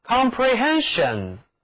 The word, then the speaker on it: comprehension: 我们在 comprehend 结尾把字母 d 换成名词后缀 sion，就成了 comprehension， comprehension 名词，理解领悟，或者表示某一种理解能力。在这里呢 SION 读音是 SHEN， Comprehension。